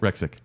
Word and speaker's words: Rexnick: So from the I Am the Listener tier, we'd like to thank Terry Finley, A More Civilized Podcast, Jordan Rexnick. Did I say that right? – Rexick. – Rexnick. – I think there's an N in there. – Rexick. – Lemony Snicket. – Rexick. I got it.